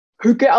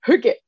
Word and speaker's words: hygge: Ja, und der Begriff Hücke kommt aus dem Dänischen und wird Hücke ausgesprochen, also mit Ü. Es hat auch nichts mit hügelig zu tun oder so, sondern es wird wirklich, also es wird geschrieben H-Y-G-G-E und wird ausgesprochen wie H-Ü. G-G-E, ja, also Hücke.